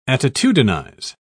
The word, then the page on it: attitudinize: British English